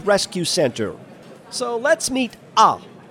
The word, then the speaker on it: Ah: Did I just say Ah? Yeah, that's how they spell his name, A-H. Now, I've never met a cat or a dog named Ah, but it's pronounced like a sarcastic scream.